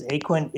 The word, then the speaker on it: Aquant: Well, everybody does, because Aquant is the correct pronunciation, but the number of people who get that right are very few and far between, so don't worry about it, but it is Aquant. – Aquant, not Aquant. – So yes. – A-Q-U-E-N-T.